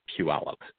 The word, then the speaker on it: Puyallup: Yeah, it's I live in a town called Puyallup.